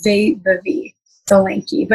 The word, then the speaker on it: Vaibhavi Solanki: Yeah, so the proper way is Vaibhavi Solanki, but you were very close and it was a great effort.